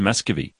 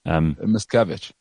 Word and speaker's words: Miscavige: Muscovy. – Miscavige.